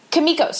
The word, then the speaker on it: Kimiko: Is it Keiko or Kiko? – Kimiko? – Kimiko, sorry.